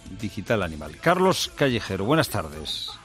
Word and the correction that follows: Digitanimal: Mira, es Digitanimal.